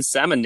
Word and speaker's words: salmon: I think a lot of other people get to 0-5 and be like, nah, I'm just going to start pronouncing the L in salmon now.